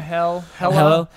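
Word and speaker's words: Hela: Cate Blanchett's character, Hallel? Is that how you pronounce it? – Yeah, Hella. – I think it's Hallel. Or Hella? – Hella. – Yeah. Hella. They actually wanted her to be, I think, in Dark World.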